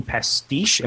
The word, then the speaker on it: pastiche: You talk about the TMNT being pastiche. Am I saying that right? – Yep.